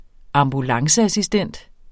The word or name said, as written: ambulanceassistent